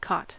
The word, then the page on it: cot: American English